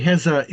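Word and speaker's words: Heza (غزة): It's When you say Heza, is that actually a Arabic pronunciation of Gaza? – Yes. Gaza is how we say Gaza. Yeah, that's correct.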